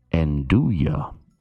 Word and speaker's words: nduja: The correct pronunciation is Andouille. Andouille. – Okay. This is a spicy pork sausage in the pork family. Here it comes. Handing her the sausage. – Okay. N-D-U-J-A. – Yes, that's how it's spelled. N-D-U-J-A. – I was like, okay, that's an interesting way to pronounce it. – All right. So it's, I don't think I've ever had this, but Andouille. Andouille? – Correct. – I'm going to give you a correct. It's Andouille, which is pretty close. N-D-U-J-A.